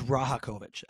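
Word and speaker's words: Rajaković: Is the J silent? Do you say the J? – Rajakovic? – There's too many variables in the I think it is Rajakovic, actually. I think it's Rajakovic. – What is it? – It's R-A-J-A. So Rajakovic. Yeah. K-O-V-I-C.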